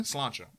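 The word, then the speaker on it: Sláinte: Slancha.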